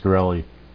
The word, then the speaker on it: Martin Shkreli: Martin Shkreli. – Is that how it is? – That is correct.